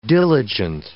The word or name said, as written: diligent